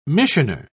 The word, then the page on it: missioner: British English